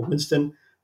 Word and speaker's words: Wynston: Just look for my name, Michael Winston. Last name is spelled W-Y-N-S-T-O-N.